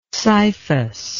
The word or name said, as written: scyphus